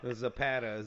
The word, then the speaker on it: Zapata: Zapata. Z-A-P-A-T-A.